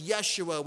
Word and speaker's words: Yeshua: He took on a human name, the Jewish name of Joshua, which is pronounced Yeshua, which we translate as Jesus.